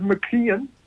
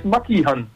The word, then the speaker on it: Makihan: Ik zei altijd McKeon, zoals McDonald's, maar het is Makihan. – Makihan. – Met de klemtoon op Han.